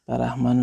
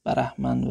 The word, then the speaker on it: برهمن: برحمن و اینا چی است و دوستان اگر یادشون باشه در این مورد توضیح دادم گفتیم که هر دو تلفظ یعنی برحمن و برحمن معنی اون پیشوای هندوان مثلا